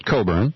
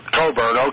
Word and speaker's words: Cockburn: Yeah, he pronounces it Coburn. – Coburn, okay, let's be fair about that.